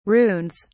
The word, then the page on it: runes: British English